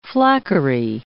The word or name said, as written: flackery